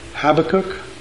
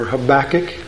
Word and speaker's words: Habakkuk: It's a phrase out of the book of Habakkuk, or Habakkuk, however you would like to pronounce it. Habakkuk is the way I grew up because I sang the books of the Bible, and you have to sing Habakkuk to get it to rhyme. But I understand it's pronounced Habakkuk.